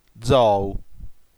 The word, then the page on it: zou: dzò.u; ['dzɔu]